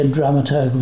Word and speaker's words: dramaturg: our dramaturge. Am I pronouncing that correctly? Dramaturge? – It would normally be dramaturg.